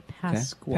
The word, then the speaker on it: Pascua: P-A-S-C-U-A, Pascua.